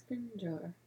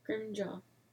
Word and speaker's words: Scrimgeour: I think it's Scrimjar. – I never know what to say. Scrimjar? – Yeah. – Scrimjar? – Is that how it's spelled? – S-C-R-I-M-G-E-O-U-R. Scrimger? – Scrimjaw. – Scrimjaw? – Yeah. – Like, not even pronouncing the R.